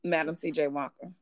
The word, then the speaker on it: Madam C.J. Walker: I think it's Adam C.J. Walker. – Madam C.J. Walker. – Madam C.J. Walker, right.